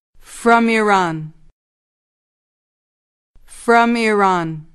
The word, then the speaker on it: from: From. – دقت کنید که برای تلفظ این کلمه نگویید فرام، باید بگویید فرام، یعنی حرف F و R باید به صورت سریع و بدون فاصله بیان شوند.